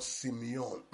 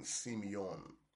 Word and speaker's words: semeion: O-T-H. That's the word signs. And in the Greek, the word signs is the word Simeon. S-E-M-E-I-O-N. Simeon. S-E-M-E, Simeon, Simeon, Simeon.